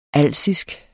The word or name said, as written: alsisk